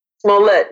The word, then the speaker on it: Smollett: Am I pronouncing his name right? – Jesse Smollett? – Smollett, yes.